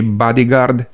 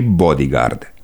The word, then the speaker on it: bodyguard: Trec direct la formele corecte și vă spun că putem pronunța și bodyguard și bodyguard. La cuvântul spray avem doar această pronunție, spray.